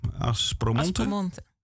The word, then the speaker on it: Aspromonte: Ze onthult de landschappen van het Aspromonte? – Aspromonte. – Zeg ik het goed? Aspromonte. Ik moet het een beetje Italiaans klankje geven.